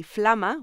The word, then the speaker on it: Flama: Joanna Hausman is a writer and a comedian with the Flama, which rhymes with llama.